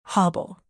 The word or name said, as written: hobble